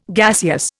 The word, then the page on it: gaseous: Indian